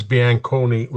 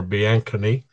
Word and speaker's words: Bianconi: Charles Bianconi was his name, or Bianconi. They pronounced it differently in different parts of the country.